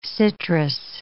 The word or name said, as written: citrus